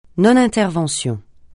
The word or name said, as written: non-intervention